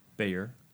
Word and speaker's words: Bayer: Bayer. – Bayer. – It's like the Bayer. It's like the headache medicine.